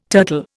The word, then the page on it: turtle: Indian